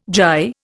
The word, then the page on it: gyre: Indian